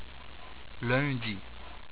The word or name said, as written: Lundi